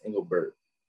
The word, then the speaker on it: Engelbert: Commissioner Kathy Engelbreit. Did I say that right? – Engelbreit? It's Engelbert.